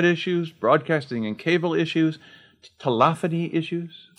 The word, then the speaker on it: telephony: Yes, I've always liked telephony. Unlike some people we know who pronounce it telephony. – Right. – Never liked that.